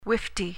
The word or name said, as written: wifty